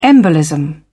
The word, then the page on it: embolism: British English